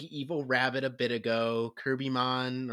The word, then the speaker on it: Cherubimon: The actual Roman, the actual official romanization is Cherubimon, but it's just written Kirpimon because someone fucked up transliterating a while ago and it just sort of stuck.